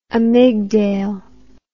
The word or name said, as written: amygdale